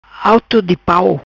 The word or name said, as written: Autodipau